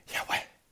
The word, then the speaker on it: Yahweh: Without vowels, it is pronounced like a whisper, a breath of wind. Yahweh!